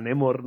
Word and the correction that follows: Namor: ¿no? – Namor. – Namor, perdón.